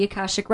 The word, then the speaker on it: Akashic: So first of all, tell everybody, what are the Akashic Records? And am I saying it right? – Yes.